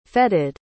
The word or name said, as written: fetted